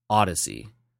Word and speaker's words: ODY-C: It's described as a gender bent, eye popping, psychedelic science fiction odyssey, which is funny because it's Odyssey. It's spelled ODY-C, Odyssey.